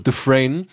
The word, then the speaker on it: Dufresne: der den Andy Dufresne, wie wird das ausgesprochen? – Dufresne.